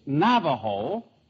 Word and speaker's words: Navajo: In Spanish, you pronounce the J like an H. It's Navajo and La Junta.